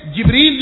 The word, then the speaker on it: جبریل: وہ وہاں گاف نہیں پڑے گا, جیم پڑے گا. اس لیے کہ جبریل جس طرح لکھا جاتا ہے تو جیم کا یہ تلفظ جبریل کرنا, اس کو جبریل نہ کہنا.